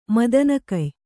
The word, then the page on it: ಮದನಗೆಯ್: Kannada